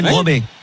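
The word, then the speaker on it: Brobbey: Et dans la vidéo, il dit Broby. Après, on peut la prononcer à la française. – Brian Broby. Ouais. C'est entre le A et le I.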